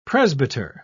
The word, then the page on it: presbyter: British English